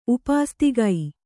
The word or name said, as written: ಉಪಾಸ್ತಿಗೈ